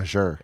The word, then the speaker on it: Azure: Did I say it right? – Azure. – Fuck me. God damn.